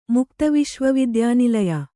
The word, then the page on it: ಮುಕ್ತವಿಶ್ವವಿದ್ಯಾನಿಲಯ: mukta viśva vidyānilaya